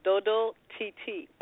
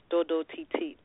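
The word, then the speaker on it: Dodo Titit: Doo-Doo TT? Is that how you pronounce yeah. – it? – Yeah. – I like that a lot. – Oh. So it's actually pronounced Dodo TT, but it's supposed to have the T at the end for titit, because okay.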